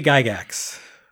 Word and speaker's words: Gygax: Gygax? – Jerry Gygax.